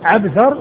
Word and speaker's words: عبثر: عبثر وعنبر. فعبثر بن القاسم هو بالعين والباء والثاء والراء، وعنبر الذي هو جد محمد بن سواء هو بالعين والنون والباء والراء،